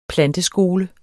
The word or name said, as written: planteskole